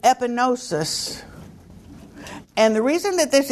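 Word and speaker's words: epignosis: You see, the G is not pronounced. That's a help. Look at a word we use today, and it'll tell you how to pronounce it in Greek. The G is not pronounced. Epinosis, to know.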